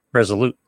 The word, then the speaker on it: Resolute: Resolve, right? No. – Yeah. – How do you say it? R-E-S-O-L-U-T-E. – Resolute. – Resolute. There you go.